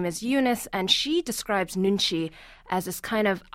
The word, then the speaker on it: nunchi: In the book, you allude to a Korean idea of nunchi. Am I saying that right? – Nunchi, yeah. Oh, very close. Yeah, nunchi.